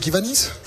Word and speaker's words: Kiwanis: parce que j'imagine Robin que le Kiwanis, je sais pas comment il faut le prononcer, Kiwanis.